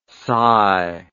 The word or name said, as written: sci